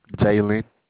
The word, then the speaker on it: Jalen: Jalen. – James? – Jalen. – Could you spell that for me? – J-A-L-E-N.